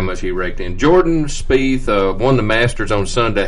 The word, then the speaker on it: Spieth: How much money did Mr. Is it Spieth? Is that the way you pronounce it? – Spieth. – Spieth.